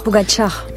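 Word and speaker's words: Pogačar: Je sais pas bien prononcé, là, Pogacar. – Pogacar. – Pardon, alors Pogacar.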